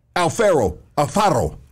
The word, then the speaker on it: Alpharaoh: Alex Alpharaoh. Let's go. Did I say that right? – Yeah, you said it right.